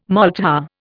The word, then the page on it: maltha: Indian